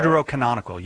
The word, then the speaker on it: Deuterocanonical: As you guys call them, Deuterochronicle? I think that's how you say it. – Deuterocanonical, yes. Yes.